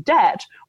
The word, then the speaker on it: debt: But the general principle of things, so if you're talking about like the silent B in debt, which like if we had a more decentralized writing system, we could one day stop writing that silent B.